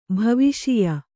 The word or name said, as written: ಭವಿಷ್ಯ